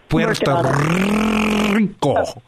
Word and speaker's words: Puerto Rico: You need to roll your R's a little bit more. Puerto Rico.